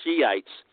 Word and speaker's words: Shiites: There are several, but the two biggest are the Sunni and the Shiites, spelled S-H-I-I-T-E-S, I think it is. It's not what you want to pronounce it. It's the Shiites.